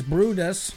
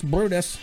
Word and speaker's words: Bryde's: This lady and another scientist noticed years ago that DNA samples from the Gulf whale seemed different from other Bryde's, pronounces Brutus. So B R Y D E S is pronounced Brutus.